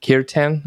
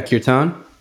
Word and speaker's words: Kirtan: Have you done any work with mantra or curtain, Kirtan, I think it's pronounced. – Yeah, yeah, Kirtan.